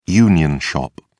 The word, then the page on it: union shop: British English